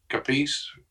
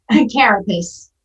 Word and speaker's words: carapace: how's that pronounced? Capice? – Carapace.